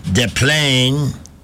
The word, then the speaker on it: Des Plaines: Well, actually, when I was growing up, Des Plaines, which is the correct pronunciation, by the way, even though nobody says that, all those northwestern suburbs were, that's where everybody who was fleeing the city went up that way.